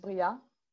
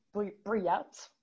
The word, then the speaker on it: Briat: My full name is, it's Violaine, and the last name is Briat. Briat, like the Americans say.